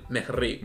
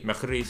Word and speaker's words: Mejri: Y tercero tenemos al turco Salah Meher. Espero estar pronunciándolo bien. Mejri. Mejri, sí.